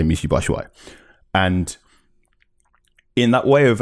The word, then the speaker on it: Batshuayi: You know, Euro 2016, UEFA did this thing about how to pronounce player names, and it was Batshuayi, and I went, okay, this is a good football player for Marseille.